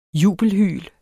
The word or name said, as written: jubelhyl